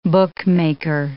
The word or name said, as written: bookmaker